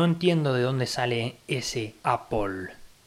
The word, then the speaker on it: Apple: Pero bueno, sabemos que los norteamericanos tienen una forma exclusiva de pronunciar las cosas, pero lo correcto es decir Apple.